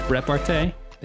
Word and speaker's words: repartee: I think that's a weird way to pronounce it. – How do you pronounce it? – Less sexually violating. – Repartee. – There it is.